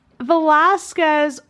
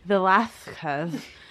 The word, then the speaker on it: Velázquez: Velázquez, or as the Habsburgs would have pronounced his name, Velázquez.